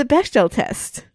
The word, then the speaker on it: Bechdel: We have – it passes the Bechdel test. – Right. And yes, exactly. Easily. – Bechdel test. I can never say that right. – You said it right the first time.